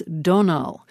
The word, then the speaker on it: Domhnall: It's Donal. Full name, Donal Gleeson. Another name now, the capital city of Scotland, spelled E-D-I-N-B-U-R-G-H.